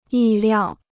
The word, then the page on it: 意料: yì liào